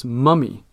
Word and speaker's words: mummy: And also in the UK, they pronounce mommy as mummy.